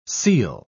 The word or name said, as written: seel